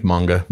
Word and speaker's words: manga: You're talking about like anime and uh, I'm probably going to mispronounce it. Uh, manja? I think it's manga.